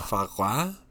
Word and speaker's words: foie gras: Foie gras. Did I say that right? Foie gras. – Foie gras. – Yeah.